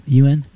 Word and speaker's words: yuan: Yuan? – Yuan? – Yeah.